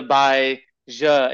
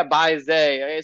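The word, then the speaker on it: Bai Ze: Yeah, I hear like Bai Zhe, The dude in the video I was saying Bai Z, and I'm like, oh yeah, Bai Zay. It's spelled ZE.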